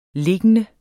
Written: liggende

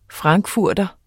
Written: frankfurter

